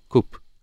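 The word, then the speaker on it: Koop: K-O-O-P. K-O-O-P. Coop.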